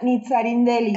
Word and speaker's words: Nitzarindeli: Es Nitza con, con TZ Nitzarindeli.